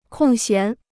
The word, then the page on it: 空闲: kòng xián